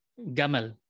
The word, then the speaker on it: gamal: So, in northern Egypt, you'll pronounce the Arabic letter jim, j, as gim, you know. They'll say gamal, you know, gamal bin Nasser, right?